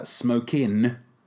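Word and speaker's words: Smokin': If you're pronouncing a G there, you're goddamn wrong. Smoking Aces.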